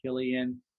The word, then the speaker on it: Killian: And if you like being tricked by my posts, or if you're on Twitter, you can find me at Chris Killian, and my last name spelled like the beer.